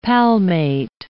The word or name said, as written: palmate